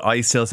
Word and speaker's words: Istil: Is it Istil or Istil? – We call it Istil, so Irish pot still vodka, 38%.